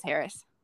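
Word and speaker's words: Harras: So then on June 20th, one of the two living Wonder Dogs, Harris, I believe is how you say it. It's H-A-R-R-A-S. So I think it's Harris. – Yeah, it's H-A-R-R-A-S. Yeah, it's Harris.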